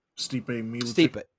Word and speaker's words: Stipe Miocic: That card, man, because you had that was when Aleister Overeem fought Step Michik. Is that how it's pronounced? – Stipe Miocic. – Stipe, yeah. Vericio Verdum beat Travis Brown.